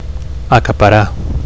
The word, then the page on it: acaparar: Catalan